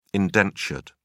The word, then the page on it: indentured: British English